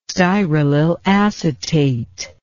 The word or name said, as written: styralyl acetate